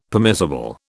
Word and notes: permissible: British English

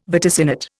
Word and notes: vaticinate: Indian

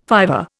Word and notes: fiver: Indian

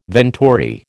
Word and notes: venturi: American English

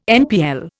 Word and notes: NPL: Indian

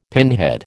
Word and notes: pinhead: American English

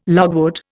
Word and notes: logwood: Indian

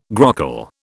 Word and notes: grockle: British English

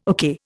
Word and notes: okay: Indian

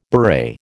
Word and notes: beret: American English

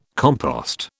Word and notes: compost: British English

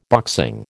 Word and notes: boxing: American English

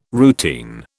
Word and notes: routine: British English

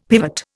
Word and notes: pivot: Indian

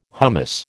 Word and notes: hummus: American English

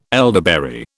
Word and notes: elderberry: British English

